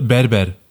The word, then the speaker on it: Berber: Es gab bei der Moschee auf der äh, Fenloer Straße gab's einen Babir. Oder Ber Babir, sagt man, ne? Habe ich das gut ausgesprochen? Also du meinst das türkische Wort? Ja. Äh, Berber. Berber. Ja, genau, das war's.